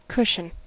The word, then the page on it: cushion: American English